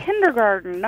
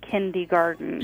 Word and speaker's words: kindergarten: It's kindergarten, not kindergarten. – Yeah, just look how it's spelled.